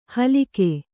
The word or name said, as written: ಹಲ್ಕ